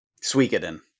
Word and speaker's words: Suikoden: Suikoden. Yeah. – Suikoden, sure. – Yeah. Yeah, I don't know. Yeah, it's spelled Suikoden. But yeah, it's pronounced Suikoden, evidently.